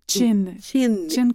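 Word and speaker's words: Qin: Chin, comme quand on fait chin-chin.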